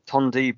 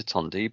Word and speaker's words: Tondu: Tondi Boots, but how would you find Tondi Boots on Twitter? Because it's not how you say it. – Tondi is T-O-N-D-U.